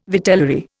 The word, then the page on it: vitellary: Indian